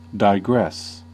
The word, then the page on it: digress: American English; [daɪˈgrɛs]